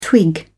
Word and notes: twig: British English

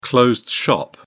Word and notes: closed shop: British English

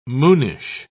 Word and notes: moonish: British English

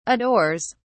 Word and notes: adores: British English

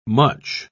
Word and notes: mutch: British English

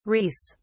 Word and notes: reis: British English

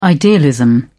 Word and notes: idealism: British English

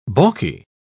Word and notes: balky: British English

